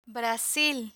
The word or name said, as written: brasil